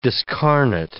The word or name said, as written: discarnate